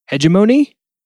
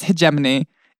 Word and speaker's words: hegemony: Hegemony? Yeah, I actually mispronounced it for a long time. Hegemony, but it's hegemony.